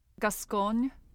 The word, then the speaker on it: Gascogne: So this the region that this comes from is the Cote Gascone or yeah, Gascone, that's how you say it, right? – Gascogne.